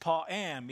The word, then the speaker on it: pa'am: This word is from a Hebrew word which is pronounced pa'am.